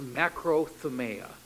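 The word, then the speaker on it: makrothumia: If you go to Strong's Comprehensive Concordance, this word would actually be rendered in the Greek as makrothumeia. That's spelled with a K, M-A-K-R-O-T-H-U-M, makrothumeia, I-A, makrothumeia, T-H-U-M-I-A, M-A-K-R-O-T-H-U-M-I-A.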